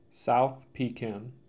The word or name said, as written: South Pekin